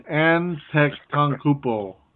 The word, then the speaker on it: Antetokounmpo: Okay, I had it before, but it's Antetokounmpo. I had it. Antetokounmpo.